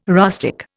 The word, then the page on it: rustic: Indian